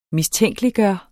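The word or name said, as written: MISTÆNKELIGGJORTE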